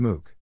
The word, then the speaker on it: MOOC: The genre is called Massive Open Online Courses, and there is one actually named MOOC, Pronounced MOOC.